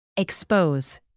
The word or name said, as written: expose